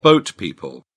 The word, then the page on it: boat people: British English